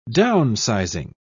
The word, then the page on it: downsizing: British English